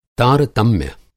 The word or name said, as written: तारतम्य